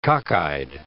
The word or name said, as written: cockeyed